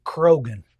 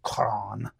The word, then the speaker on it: Croghan: You'll want to pronounce it Crogan, C-R-O-G-H-A-N, but it's a Gaelic name. So it would have been pronounced Cron. It was, you know, with a deep brogue.